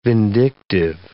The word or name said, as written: vindictive